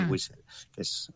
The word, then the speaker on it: whistle: A ver si lo pronuncio bien. Es el whistle. O whistle. – Whistle, efectivamente.